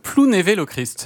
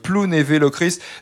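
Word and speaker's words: Plounévez-Lochrist: J'ai fait attention, j'ai à peu près prononcé correctement, parce qu'il y a un Z à la fin, mais on dit Plounévé-Locrist.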